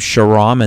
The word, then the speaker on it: Shahram: So again, my name is spelled S-H-A-H-R-A-M. That's the first name, Sairam. And then H-A-D-I-A-N as in Nancy, Haydian. So if they just look up Sairam Haydian on Rumble, they will find our page, and we'll be uploading that, as well as all of our Truth Today shows.